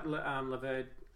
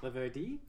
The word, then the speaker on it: Laverde: Like a guy like Laverde. I got told on Twitter that I should be pronouncing it Laverde.